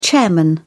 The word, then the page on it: chairman: British English